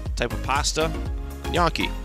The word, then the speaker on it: gnocchi: I used to say Yonki. – Yeah. And I mean, even that one's a little bit better than like Ganakis.